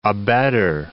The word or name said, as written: abetter